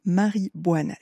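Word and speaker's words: Mari Boine: Je vous le donne en francisé la prononciation. Marie Boine, c'est elle qui chante cet air akai, donc du yoik.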